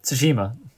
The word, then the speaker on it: Tsushima: How have you not learned how to say the word Tsushima yet? – Tsushima, I said that. – Yeah, you still stumbled. Tsushima.